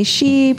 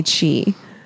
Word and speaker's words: Chi: And it's like, and then people call me She, people call me Shay. It's weird how no one can just call me Chi.